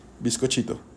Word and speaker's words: bizcochito: Y en inglés se pronuncia exactamente es, eh, igual, bizcochito.